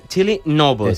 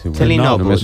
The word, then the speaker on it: Tilly Norwood: Vaig a dir-ho bé en pronunciació bé. Tilly Norwood. – És igual. – Tilly Norwood.